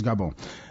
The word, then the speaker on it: Gabon: The correct French or phonetic pronunciation is Gabon.